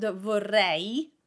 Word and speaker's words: vorrei: When you pronounce the word vorrei, we have an E sound at the end, and you almost have to smile to produce that sound. It is not vorrei, it is vorrei, vorrei, and you're smiling to produce that E sound.